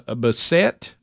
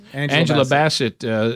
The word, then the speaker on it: Bassett: When Jamie Lee Curtis won her first ever Oscar for Best Supporting Actress, that Angela Bassett, is that how you say her name, or is it, is that how you say your last Bassett. – Name? – Bassett. – Angela Bassett.